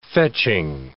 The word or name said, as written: fetching